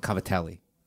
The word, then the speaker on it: Cavatelli: Covadils. – Called Cavatelli. The way it's spelled.